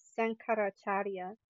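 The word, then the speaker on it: Shankaracharya: There wells up within the heart of the yogi the song of soul, sung by Sankaracharya. There we go, I said it right.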